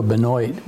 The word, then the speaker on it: Benoit: But when we started producing wine, we were making Pinot Noir, and you can't be Chateau Benoit selling Pinot Noir.